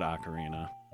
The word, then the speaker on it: Ocarina: Yeah, which I've always said Ocarina. – Yeah. – But I'm pretty sure that's the correct pronunciation, right? – I think so, too. But I know Ocarina? When I was a kid, a lot of people said Ocarina.